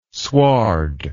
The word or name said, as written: sward